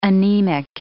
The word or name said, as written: anemic